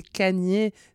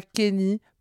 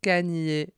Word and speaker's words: Kanye: c'est Kanye, c'est pas Kenny, pas Kanye. Kanye.